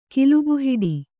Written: ಕಿಲುಬುಹಿಡಿ

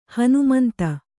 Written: ಹಣಮಂತ

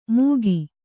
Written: ಮೂಕಿ

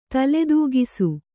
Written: ತಲೆದೂಗಿಸು

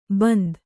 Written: ಬಿಂದು

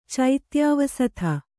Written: ಚೈತ್ಯಾವಸಥ

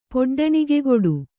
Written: ಪೊಡ್ಡಣಿಗೆಗೊಡು

